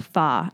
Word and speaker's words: pho: It's pronounced pho, actually, which I learned that one if I was there.